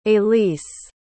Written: alease

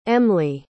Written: emmly

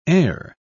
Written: e'er

